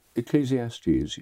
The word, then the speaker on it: Ecclesiastes: So I don't know if I uh, pronounce this correctly. Ecclesiastes, that Bible book. – Ecclesiastes, yes.